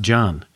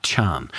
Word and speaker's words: Can: that Emre Can's name is pronounced John and not Chan.